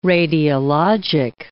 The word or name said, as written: radiologic